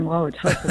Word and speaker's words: Wauwatosa: Wauwatosa, Wisconsin. Go ahead with your question. – Very good on pronouncing Wauwatosa.